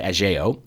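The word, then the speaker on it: Ajao: and a boy in her class played by Michael Ajo, or I should say Ajeo, pardon my pronunciation.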